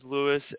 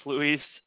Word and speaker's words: Luis: I go by Louis. I actually got that question asked earlier. So I get scolded by the Hispanic culture because I refer to myself as Louis and my name is pronounced Luis.